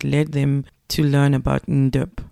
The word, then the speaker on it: Ndox: It's more Ndop.